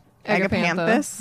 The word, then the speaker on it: Agapanthus: But these, how do you say this? Agapantha? – Agapanthus? Yeah. Agapanthus. Yeah.